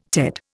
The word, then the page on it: Ted: Indian